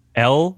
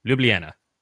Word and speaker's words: Ljubljana: It's spelled like this: Ljubljana. Ljubljana.